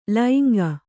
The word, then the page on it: ಲೈಂಗ: lainga